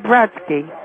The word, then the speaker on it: Brodsky: I said Brodsky. – How do you spell that? – B-R-O-D-S-K-Y. – B-R-O-D-S-K-Y. – No, S-K-Y. – S-A-Y.